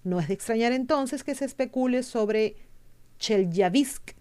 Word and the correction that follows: Chelyabinsk: Ah, perdón la pronunciación, la pronunciación, Chelyabinsk, perdón, como escenario de una invasión alienígena.